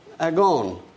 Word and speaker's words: agon: but it is pronounced agon. In a moment, you'll see why we're talking about this. If it is written phonetically in English letters, it'd be A-G-O-N, pronounced agon.